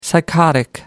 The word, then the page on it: psychotic: American English